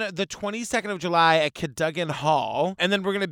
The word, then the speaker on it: Cadogan: Pronounced Cadogan. It looks like Cadogan, but it's Cadogan.